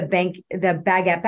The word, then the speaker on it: baguette: Oh, I think I've got everything I've coveted, but it was mostly the Fendi stuff, the baguette bags. Did I just say that right? – Baguette. – I almost said banquette and I'm like, no, that's not right.